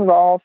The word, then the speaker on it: Rohlf: So if you Google Dressage Naturally, or Karen Rohlf, and the last name is spelled R-O-H-L-F. Throw that H in there just to mess everybody up.